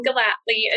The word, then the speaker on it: Gellatly: Let's correct it for everyone. Is it Gellatly? Okay, yes.